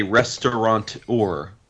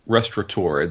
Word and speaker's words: restaurateur: but it's not a restaurateur, it's a restaurateur. There's no N. – Oh. – There's no NT.